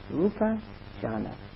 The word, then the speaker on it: rupa jhana: In Pali, just rupa jhana. Four syllables.